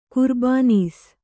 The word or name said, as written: ಕುರ್ಬಾನೀಸ್